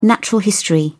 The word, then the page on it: natural history: British English